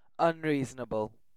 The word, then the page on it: unreasonable: British English